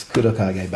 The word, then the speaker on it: Kurokage: Shaft, that is how you pronounce it. I don't know if we probably say what? Kurokage? I don't know how we'd say it as Americans, but it's Kurokage.